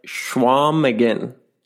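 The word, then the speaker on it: Chequamegon: You don't pronounce the G. It's just, it's like Schwamigan.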